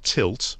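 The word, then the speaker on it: Tylt: It's from a company called Tilt, which is spelt with a Y, so it's T-Y-L-T.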